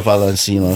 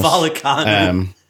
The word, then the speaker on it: Vallecano: Rio Valencianos. – Vallecano.